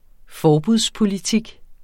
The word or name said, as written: forbudspolitik